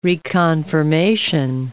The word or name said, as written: reconfirmation